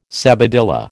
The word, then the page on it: sabadilla: American English